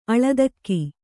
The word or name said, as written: ಅಳದಕ್ಕಿ